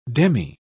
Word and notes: demi-: British English

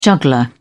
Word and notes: juggler: British English

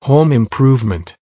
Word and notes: home improvement: American English

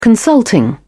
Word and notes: consulting: British English

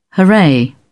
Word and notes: hurray: British English

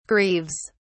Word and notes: grieves: British English

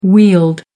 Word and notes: wheeled: American English

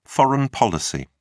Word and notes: foreign policy: British English

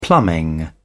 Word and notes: plumbing: British English

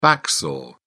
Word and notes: back saw: British English

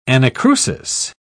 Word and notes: anacrusis: British English

